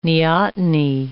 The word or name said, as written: neoteny